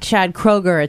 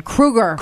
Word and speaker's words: Kroeger: Similar to the fact that after years and years and years, we realized that from Nickelback, it's not Chad Kroeger, it's Chad Krueger.